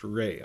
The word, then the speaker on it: Rhea: And I thought you pronounced her name Rhea, by the way, because it's R-H-E-A, but it's pronounced Ray, I realized, in an interview with the other cast members.